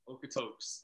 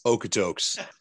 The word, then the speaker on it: Okotoks: Okotoks? Did I say that right? – Okotoks. – Okotoks.